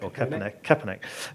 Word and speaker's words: Kaepernick: So you've had things like Nike supporting the NFL player Colin Kaepernick. I hope I've said that right. – Kaepernick.